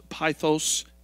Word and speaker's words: pythos: It's pronounced pythos in the Greek, in the original language of the New Testament.